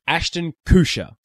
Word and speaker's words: Ashton Kutcher: Ashton Kutcher.